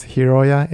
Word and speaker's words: Hiroya: My first name is Hiroya, H-I-R-O-Y-A. Mm-hmm. Last name is Tsukamoto, T-S-U-K-A-M-O-T-O.